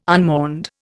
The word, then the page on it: unmourned: Indian